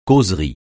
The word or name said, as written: causerie